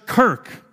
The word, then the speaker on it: kerk: Kirisi, related to the Dutch kirk. Okay, I got a bunch of Dutch people here. K-E-R-K. Am I good with that? Kirk. Not Captain Kirk.